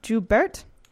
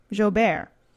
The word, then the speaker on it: Joubert: Hervé Joubert. – Thank you. Joubert. That is way more French than what I said.